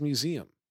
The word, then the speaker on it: museum: And then there was my wife claiming that she pronounces museum in some way that doesn't rhyme with Liam.